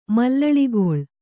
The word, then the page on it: ಮಲ್ಲಳಿಗೊಳ್: mallaḷigoḷ